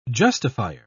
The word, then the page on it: justifier: British English